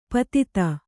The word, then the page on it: ಪಾಥೋದಿ: Kannada